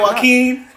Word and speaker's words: Joaquin: Well, Jaquan, how you say his name? – Can you spell? – Joaquin. – How you pass high school? I don't know. They just giving out diplomas. It's like, look, man. The J is silent.